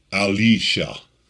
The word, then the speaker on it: Elisha: It's pronounced Elisha, where the I's become almost E's in the Hebrew.